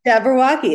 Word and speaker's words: Jabberwocky: Jabberwocky? – Yes. – Okay. Jabberwocky.